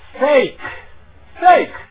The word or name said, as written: fake